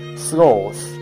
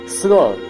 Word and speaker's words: sloth: One of them is sloth, which the presenter pronounced sloth to rhyme with cloth. But this is not my pronunciation. I call it sloth to rhyme with both and growth.